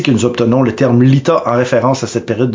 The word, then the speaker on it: Litha: Bon, Lita se prononce Lita, mais c'est assez facile à comprendre.